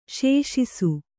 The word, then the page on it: ಶೋಷಿಸು: śoṣisu